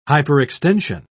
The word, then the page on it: hyperextension: British English